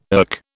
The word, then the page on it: irk: British English